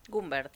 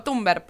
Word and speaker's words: Thunberg: Thunberg. – ¿Sí? Thunberg, perdón.